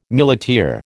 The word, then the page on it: muleteer: American English